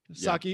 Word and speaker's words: Psaki: Psaki.